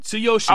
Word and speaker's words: Tsuyoshi: Tsuyoshi, all right.